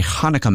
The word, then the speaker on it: Hanukkah: Hanukkah.